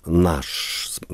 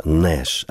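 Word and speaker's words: Nash: И в итоге я везде стал писать «Наш», как фамилия такая. Может быть, нужно было там тоже звездочку поставить, потому что произносится все-таки это «Нэш», а не «Наш».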